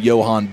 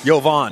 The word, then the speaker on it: Jovan: Well, it's Jovan Buha. – Okay. – I just loved what you said. – I called him Johan Buva.